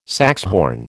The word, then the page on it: saxhorn: American English